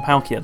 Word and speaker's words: Palkia: Palkia's origin form. Palkia, that's it.